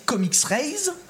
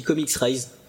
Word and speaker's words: ComiXrays: Toi, tu le prononces comment moi, je dis Comics Rise parce que ça s'écrit hmm. Comics Rise en fait.